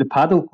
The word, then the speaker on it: Padel: There's something called, is it Padel Club? Is that, am I pronouncing it right? – Yeah, the Padel Club. Yeah, Paddle.